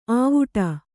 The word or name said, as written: ಅವುಡು